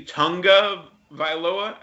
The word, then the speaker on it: Tagovailoa: and that's Tua Tagovailoa. I learned recently that it's supposed to be Tunga Vailoa. There's definitely an N in there somehow.